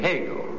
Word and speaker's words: Hegel: If you were asked, or we asked you to identify the German philosopher whose last name rhymes with a hard roll with a hole in the middle, you'd surely say Hegel. Name rhymes with bagel.